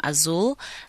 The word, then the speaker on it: Azul: So, growing up, a lot of people like could not really pronounce Azul, the name Azul. They would call me Azula, Azuri, Azui, like, you know, all those funny names.